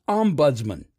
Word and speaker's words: ombudsman: The word is pronounced ombudsman. It can also be pronounced ombudsman or even ombudsman. But despite how Siri says it, the second syllable is buds, not bids.